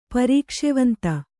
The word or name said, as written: ಪರೀಕ್ಷೆವಂತ